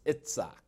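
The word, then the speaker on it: Itzak (יצחק): The Hebrew pronunciation is Itzak.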